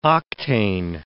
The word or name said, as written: octane